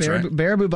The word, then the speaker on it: Baraboo: Baraboo Bombers.